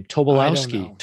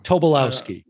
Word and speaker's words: Tobolowski: Tobolowski. Tobolowski. I Tobol- uh, think I'm going to try that and get the meter. – Going? I got it. Tobolowski.